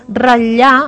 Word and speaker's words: ratllar: Podem rossejar, com l'arròs rossejat, podem torrar, estufar, fregir, sofregir, ratllar amb aquesta T que no s'ha de pronunciar, el que hem de fer és geminar la doble L, mm-hmm.